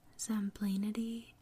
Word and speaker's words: Zemblanity: Zemlinity. I'm saying it wrong. It's spelled Zemblanity. Zemblanity.